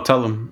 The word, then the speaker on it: Tulum: Tulum? – Oh, Tulum.